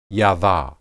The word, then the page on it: yadah: Hebrew